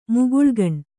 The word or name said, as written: ಮುಗುಳುಗಣ್